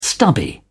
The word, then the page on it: stubby: British English